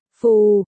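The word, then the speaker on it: phù (副): Họ phó, phu và chữ phó, phu trong phó quản lý, tổ phó, cùng đọc là, phù, vì tiếng Trung đặt họ tên trước chức phụ, nên quản lý phó hay phó quản lý đều đọc là, phù Jingli, quản lý phó giới thiệu cho Từ Bạch.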